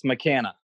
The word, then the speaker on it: McKanna: Yes, McKanna.